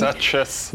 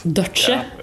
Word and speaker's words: Douches: Og studioingeniør, mikser Justin Phelps, Alan Duché, eller hvordan du uttaler det på, på mastering? – Duchess. – Duchess. Ja, jeg, der, der jeg har måttet Duchess, jeg måtte lært meg. For jeg pleide å si douches før, men det er jo ikke så fint.